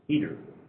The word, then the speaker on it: HT3R: call the HT3R, pronounced heater.